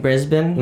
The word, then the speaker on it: Brisbane: You're saying Brisbane? – Mm-hmm. Yeah. – Did I pronounce that? – Yeah, that's really good. Most people say Brisbane.